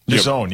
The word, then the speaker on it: DAZN: The Minnesota Timberwolves announced a new local media rights agreement with DAZN, naming the global sports entertainment platform as the franchise's new exclusive home for Timberwolves basketball across its local broadcast territory. – Is that how you say it? – DAZN, yep.